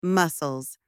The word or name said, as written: muscles